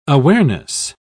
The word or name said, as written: awareness